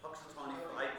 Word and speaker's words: Punxsutawney: Punxsutawney Phil. Some Native American pronunciation. – That meant the Phil part. No, actually, I meant the Punxsutawney part. Yeah, weird pronunciation, right? It's a lot of letters and vowels over there, and consonants. Punxsutawney Phil, something along those lines.